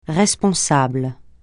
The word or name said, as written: responsable